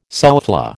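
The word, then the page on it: soufflé: American English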